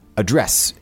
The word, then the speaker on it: address: Or can we figure out another time to address your topic? – Right. So this is a verb here, not address. – Right. – Address. – Right. The stress is on the second syllable.